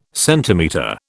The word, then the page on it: centimetre: British English